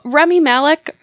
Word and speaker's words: Rami Malek: Rami Malek, is that how you say his name? – Yeah.